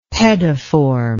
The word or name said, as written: pediform